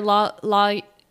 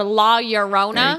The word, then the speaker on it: La Llorona: So just mosey on over to the Women in White or La Llorona. Mm-hmm. Did I say it right? – Yeah, you did. – Or La Llorona. – Good. Even better.